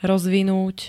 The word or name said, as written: rozvinut